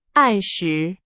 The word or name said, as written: 按时